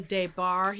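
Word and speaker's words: Des Barres: Pamela DeBar here. My ex-husband Michael always said it rhymes with gay bar, so that's how you can remember how it's pronounced. And it's spelled capital D-E-S, capital B-A-R-R-E-S.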